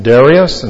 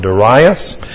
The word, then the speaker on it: Darius: and there are two different pronunciations. Some people pronounce it Darius and some Darius, and he is the new king of Babylon.